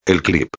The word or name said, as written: el clip